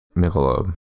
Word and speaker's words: Michelob: Michelob? – Michelob.